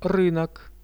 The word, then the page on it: rynok: Russian